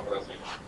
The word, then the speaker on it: Brasil: I can't do Portuguese, how would you pronounce this? Brasil.